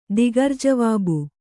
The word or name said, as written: ದಿಗರ್‍ಜವಾಬು